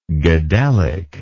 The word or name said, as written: gadhelic